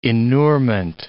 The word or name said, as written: inurement